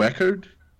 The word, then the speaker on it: Eckhardt: É Eckhart ou Eckhart, como é que pronuncia? – Geralmente, eu digo Eckhart. – Eckhart. – Tipo, é isso, exatamente, em queda. Aí fica mais fácil. – Ah, legal. Então. – Ignora metade das consoantes no nome e tá beleza.